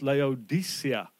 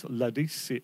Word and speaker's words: Laodicea: And it's pronounced Laodicea. That's the proper way, but I'm probably not going to do that. I'll get tongue-tied. But that's for the pedants amongst us, like me, it's Laodicea.